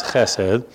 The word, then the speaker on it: chesed: It's chesed. The Hebrew word there is chesed. Often it's spelled C-H-E-S-E-D. Sometimes it's just spelled H-E-S-E-D. But either way, you're supposed to get a nice little fricative in there, a little ch. Don't clear your throat.